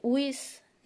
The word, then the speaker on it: wicce: esa nueva palabra Wicca, de una palabra que se escribía igual en inglés, en inglés antiguo, que significaba brujo, al tiempo que wicce ¿no? W-I-C-C-E, significaba bruja. Eh, aunque en inglés antiguo la doble C se pronunciaba como CH y no como K